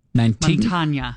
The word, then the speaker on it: Mantegna: Mantegna.